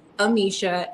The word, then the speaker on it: Amicia: My Instagram account is Amicia, A-M-I-C-I-A, TV.